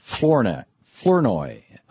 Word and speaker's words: Flournoy: Secretary Michelle Flournoy. I'm mispronouncing that, aren't I, Secretary? – No, you did very well, Tom.